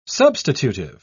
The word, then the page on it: substitutive: British English